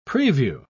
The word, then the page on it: prevue: British English